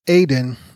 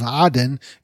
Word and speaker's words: Aden: The only part he was willing to concede and give up to the British was the port of Aden, that's the English pronunciation, the port of Aden in Arabic, which is in southern Yemen.